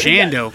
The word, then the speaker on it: Shando: It's with an O on the end instead of an L, but close enough. – Shando. All right. – Shando.